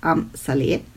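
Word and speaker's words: Amsale: Manche sagen auch Am Saleh, aber ich glaube, die korrekte Aussprache ist laut Wikipedia Am Saleh.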